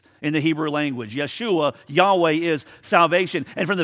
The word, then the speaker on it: Yeshua: In the Greek language, it would be pronounced Jesus. In the Hebrew language, it would be pronounced Yeshua.